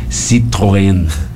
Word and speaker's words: Citroën: Citroën como dizia o, o, meu professor de, de francês, eu fiz francês, Citroën.